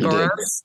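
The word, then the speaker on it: Görås: But moving on, Oscar Goras, did I pronounce that right, by the way? Is it Goras? Okay, great. So Oscar Goras and Leland are all over this album.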